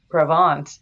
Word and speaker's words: Provence: P-R-O-V as in Victor, E-N as in Nancy, C-E, Croy Provence.